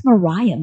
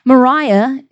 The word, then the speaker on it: Moriah: So on another whim, I'm like, what does Moriah mean? Moriah, it's actually pronounced Moria, Moria.